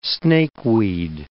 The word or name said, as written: snakeweed